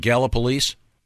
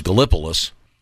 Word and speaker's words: Gallipolis: Is it Gallup Police? – Yes, that's correct. – Okay, because I used to think it was Gallipolis. Like the movie Gallipoli, but I want to make sure I get it right.